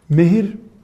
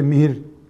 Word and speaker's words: mehir: mehir, Türkçe'de mihir diye de telaffuz ediliyor.